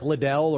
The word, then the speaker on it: Liddell: So probably most of you are familiar with Eric Liddell, or Eric Little as I always pronounced it.